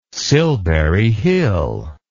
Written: Silbury Hill

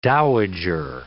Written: dowager

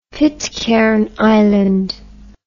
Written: Pitcairn Island

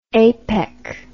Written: APEC

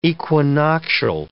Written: equinoctial

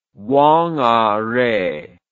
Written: whangarei

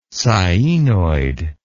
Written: sciaenoid

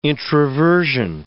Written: introversion

